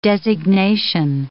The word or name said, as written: designation